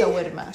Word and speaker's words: shawarma: But he pronounced it incorrectly. Incorrectly, yeah. See, shawarma.